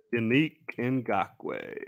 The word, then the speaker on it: Yannick Ngakoue: Yannick Ngakoue.